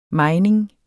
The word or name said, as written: mejning